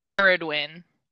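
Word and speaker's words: Cerridwen: Sirwindwin? – Oh, man. I looked this up. No, it's a you K. Did. It's like Caridwin.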